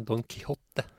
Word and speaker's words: Don Quixote: Ja, det är klart det finns så det blev postat att Don Quixote som jag funnit ut att det uttalas är det? Inte Don Quixote? – Nej, Don Quixote.